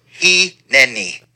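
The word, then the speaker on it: hineni (הִנֵּנִי): But the word is, as you see up there, the word is pronounced hineni. Hineni.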